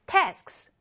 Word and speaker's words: tasks: 자, task 하고 끝에 s 붙이면 tasks. 이렇게 발음 끝에 해주세요.